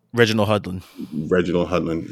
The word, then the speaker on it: Reginald Hudlin: Reginald Hudlin.